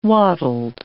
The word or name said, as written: wattled